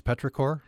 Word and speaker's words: petrichor: I wonder if you'd start with is it pronounced petrichor? – Yes.